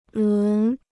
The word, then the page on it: 轮: lún